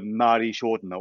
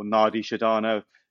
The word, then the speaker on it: Nadi Shodhana: But the one we're going to go to today is called um, uh, Nadi Shodhana or Nadi Shadana, You know, depends how you want to pronounce it.